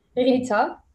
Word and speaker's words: Ghita: Ghita. So GH is like an R back here. So it's pretty much like Rita, but people say Ghita or Jita in the US, you know.